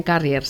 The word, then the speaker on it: Carrier: A Sonia Hernández, ingeniera industrial, representante de ventas en Carrier, se dice así.